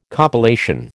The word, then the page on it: compilation: American English